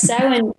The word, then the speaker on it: Samhain: And were you familiar with the story of Halloween, or, oh God, I always pronounce it wrong, but because I want to say Samhain. Yeah, I always do that wrong. So that's okay. That's okay. We take all pronunciations here. Yes, Samhain.